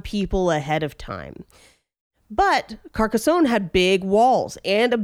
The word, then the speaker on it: Carcassonne: So that one is pronounced Carcassonne.